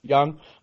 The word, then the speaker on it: Jung: Is it Josh Jung or is it Josh Young? – Young. – Okay. It's spelled with a J, but it is pronounced Young.